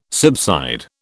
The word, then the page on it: subside: British English